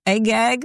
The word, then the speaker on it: Agag: Is it Agag? Agag, yeah.